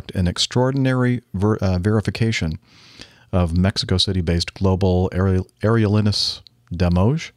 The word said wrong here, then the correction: Damojh: D-A-M-O-J-H, or Global Air, and ground the carrier during the check. – It's pronounced like an H. Damoje.